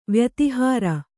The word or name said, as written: ವ್ಯತಿಹಾರ